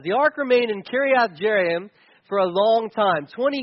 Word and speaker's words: Kiriath Jearim: And it ended up in this little town called Kiriath Jearim. That's the official pronunciation. So if you want to sound like an expert, that's what you say. Kiriath Jearim.